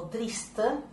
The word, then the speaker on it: Triste: Esta pieza, llamada Triste o Triste, si la pronunciamos en francés, fue publicada en la revista Elegancias en 1911.